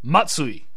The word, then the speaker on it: Matsui: It is pronounced Matsui.